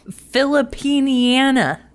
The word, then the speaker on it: Filipiniana: Filipiniana. – Is that really how that's spelled? – Yeah, like Americana. – Okay, thank you. – Or like Canadiana. Okay, Bass and Reiner has a show opening on September 10th.